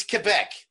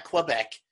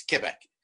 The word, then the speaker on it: Quebec: it's Quebec. That's how you pronounce that word. It's not Quebec. It's Quebec. Um, so I do have a problem as a hockey fan.